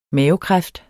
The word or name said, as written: mavekræft